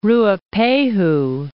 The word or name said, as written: Ruapehu, Mount